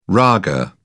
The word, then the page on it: raga: British English